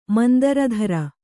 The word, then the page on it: ಮಂದರಧರ: mandara dhara